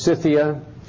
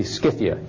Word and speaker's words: Scythia: So you see, the C can be hard or soft, depending on how we say it. Scythia, we usually pronounce it as Scythia, but it could be Scythia just as easily.